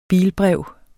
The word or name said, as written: bilbrev